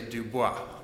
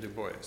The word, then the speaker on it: Du Bois: If anyone has taken French, I know you want to say Du Bois, but it's the Americanized pronunciation, Du Bois.